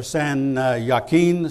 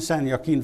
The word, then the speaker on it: San Joaquin: like San Jacinto or San Joaquin. San Joaquin Valley is spelled with a J, but it's pronounced with a Y.